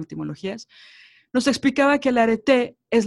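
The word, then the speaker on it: arete (ἀρετή): bueno, la cosa es que estudiando eh, en mis clases de etimología grecolatina, hay una palabra que se llama arete, así se pronuncia, ¿no?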